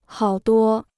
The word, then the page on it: 好多: hǎo duō